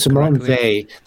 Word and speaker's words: Simone Weil: Simone Weil, the French would say.